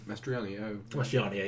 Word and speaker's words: Mastroianni: Is that how you say it? – Mastroianni, yeah.